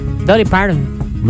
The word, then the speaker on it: Dolly Parton: Dolly Parton.